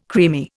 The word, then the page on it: creamy: Indian